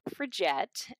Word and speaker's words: suffragette: So what does it mean to be a puffergate and how can someone become one? And did I pronounce that correctly? – Yeah. Okay. So, suffragette.